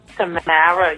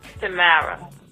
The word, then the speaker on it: Tamara: Now, you could say it, like, some people say it Tamara, some people say it Tamara, some people say Tamara. How do you say it? – Tamara.